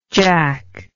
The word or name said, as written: jak